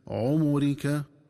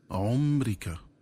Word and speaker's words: عمرك: وضم الميم هكذا عمرك، ومن الخطأ قراءته بتسكين الميم هكذا عمرك.